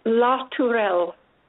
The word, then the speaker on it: La Tourelle: And Maggie, I'm going to have you pronounce your last name, because I'm not sure I was pronouncing it correctly. – It's La Tourelle. – La Tourelle. Oh, yes, I was not pronouncing it as fancy.